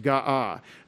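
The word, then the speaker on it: ga'ah (גאה): And the word is G-A-A-H, ga'ah. And that's gimel, aleph, hey. And we pronounce that when we write it as a verb in the perfect tense, we pronounce it ga'ah.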